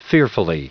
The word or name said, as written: fearfully